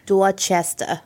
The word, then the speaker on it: Dorchester: Dorchester.